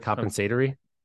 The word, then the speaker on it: compensatory: How do you pronounce that word? Compensatory? Doesn't sound right. – Compensatory. – Yep. There you go.